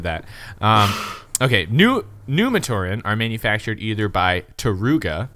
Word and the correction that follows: Turaga: It's, we spelled it, we were pronouncing this wrong. Apparently, it's not Taruga. – Sorry, Taraga. Yes, Taraga.